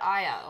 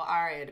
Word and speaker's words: Arya: There's even, you know, from a feminist standpoint, you know, you've got Aya or Arya, depending on what your Arya. Pronunciation is.